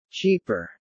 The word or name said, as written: cheaper